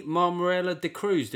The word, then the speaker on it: Mammarella-d'Cruz: Kenny Marmarella de Cruz. Did I get your name right, Kenny? – Brilliantly pronounced. I'm foreign, don't you know? – You're foreign. I mean, Marmarella de Cruz, that's almost like smacking people over your head with the foreignness.